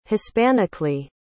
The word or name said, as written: hispanically